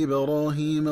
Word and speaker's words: إبراهيم: ينبغي التنبه للنطق الصحيح لكلمتي إبراهيم وربه، فتقرأ إبراهيم بفتح الميم وتقرأ ربه بضم الباء، فتكون قراءة الكلمتين معا هكذا: وإذ ابتلى إبراهيم ربه بكلمات فأتمهن. – والمعنى أن الله عز وجل اختبر إبراهيم بما شرع له من تكاليف فأداها وقام بها خير قيام.